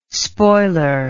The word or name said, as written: spoiler